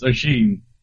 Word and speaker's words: Oisin: That's how you say your name. It's Oisin. – Is that what it is? – I think it's Oisin or Oisin? Oisin. – Oisin? – Yeah, but it's an accent. It's a Gaelic.